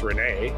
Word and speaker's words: Resnais: 2014, French film director Alain René, not to pronounce this in French, R-E-S-N-A-I-S, must be pronounced René, right?